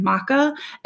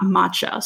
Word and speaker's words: Macha: Now, this is spelled M-A-C-H-A, but because of its Celtic roots, I am assuming that there is a hard K, making it Macha and not Macha. So forgive me if I'm saying that wrong, but I do believe it is Macha.